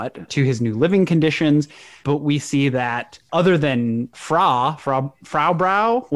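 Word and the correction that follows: Fraw Bow: how do we pronounce her name? – It's Frau Bow. – Frau Brow. Okay. – No, so Bow. Not Brow. Bow.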